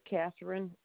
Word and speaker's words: Catherine: Okay, my name is Catherine Orsk.